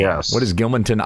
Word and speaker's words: Gilmanton: Gilmanton, New Hampshire. Did I say that right? Gilmanton? – That's correct, yeah.